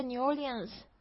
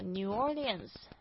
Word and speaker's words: New Orleans: 好，所以我就去了 New Orleans。那我当然啊，我一点都不后悔，我觉得真的是好棒的一个地方，而且我在那边认识的很多很可爱的人，有机会再跟大家多描述一下吧。那我们现在学这个发音，这个 New Orleans 它的拼法是，